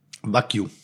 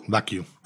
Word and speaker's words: Wagyu: Wagyu. – Wagyu? – Wagyu. – Ah, okay, da habe ich mich immer gefragt. Ich habe oder nennen es einfach japanisches Rind. – Okay, Wagyu.